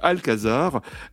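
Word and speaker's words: Al-Qasar: Alors Alcazar, ça s'écrit A-L, trait d'union, Kazar, Q-A-S-A-R, mais ça se prononce comme le Alcazar, ça veut dire palais en arabe.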